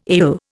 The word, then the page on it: ail: Indian